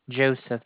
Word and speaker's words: Joseph: Joseph. – Joseph. Joseph. Hello? – That's correct, ma'am. – How to pronounce? – Joseph, J-O-S-E-P-H. J-O-S-E-P-H, Joseph. – J-O-S-E-P-H.